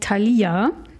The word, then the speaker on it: Thalia: Keine Ahnung, ich habe übrigens das Rätsel gelöst, es heißt Thalia. Das hat Thalia nämlich jetzt selbst aufgeklärt, wie man es richtig ausspricht.